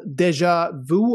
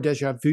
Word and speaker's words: déjà vu: You have written this book called Déjà Vu, or Déjà Vu, if we want to do it in French, the original pronunciation there.